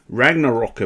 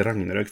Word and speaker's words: Ragnarök: Ragnarok, jag bara, nej det heter Ragnarök faktiskt.